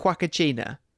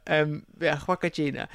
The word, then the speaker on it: Huacachina: So the Peruvian settlement of Huacachina. – Beautiful pronunciation, by the way. We can't just let that slide. – I don't know. I might have murdered it. – It sounded awesome. – Huacachina.